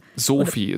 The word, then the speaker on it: Soofi: Sophie, S-O-O-F-I, haben wir gelernt, wird ausgesprochen, bisschen wie der Name, aber nicht genau.